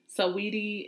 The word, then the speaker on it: Saweetie: Saweetie or whatever that girl's name is. Is that how you say it? – That's Saweetie. How I say it. – Saweetie.